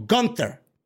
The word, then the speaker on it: Gunther: He is now named Gunther or Gunther. I say Gunther because that's the way he pronounces it.